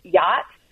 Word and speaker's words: Yat: I've read a paper on something called the Yacht dialect. Y-A-T.